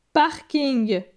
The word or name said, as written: parking